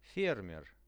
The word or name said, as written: fermer